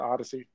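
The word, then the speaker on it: Oddisee: It's Odd IC, but you would pronounce it Odyssey.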